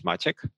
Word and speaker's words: Maciek: The proper Polish pronunciation of my less formal version of my name is Maciek.